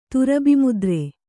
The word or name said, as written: ತುರಬಿಮುದ್ರೆ